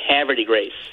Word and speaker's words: Havre de Grace: I have no idea how they say it in Maryland, but I would say Havre de Grace. – Well, you have to pretend that French were never invented and say and speak like the Beverly Hillbillies and say Havre de Grace.